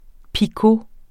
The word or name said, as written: picot